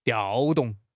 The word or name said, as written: 调动